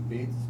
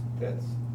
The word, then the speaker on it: Petiz: Can't pronounce his name real well. P-E-T-I-Z, Pets. Pets.